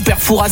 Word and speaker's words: Fouras: Eh bien écoutez, on dit Foura. On ne prononce pas le S.